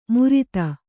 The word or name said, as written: ಮುರಿತ